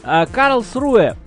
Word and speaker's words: Карлсруэ: Карлсруэ. На юге Германии, рядом Баден-Баден. На первый слог ударения.